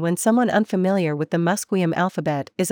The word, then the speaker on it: Musqueam: Even the spelling of the name Musqueam is an old anglicization that the community is gradually beginning to phase out. Unlike English, where characters often stand in for a variety of different sounds, the current alphabet accurately represents the historical pronunciation, Musqueam.